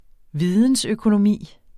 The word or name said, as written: vidensøkonomi